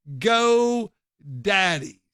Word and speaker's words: Geaux: And it's spelled like a Cajun would spell go, G-E-A-U-X, Go Daddy's.